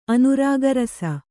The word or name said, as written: ಅನುರಾಗರಸ